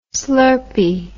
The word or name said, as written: slurpy